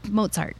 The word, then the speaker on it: Mozart: You don't need to know it's Mozart. It's most people say Mozart, and it's like pizza, Mozart, you know, and now you know.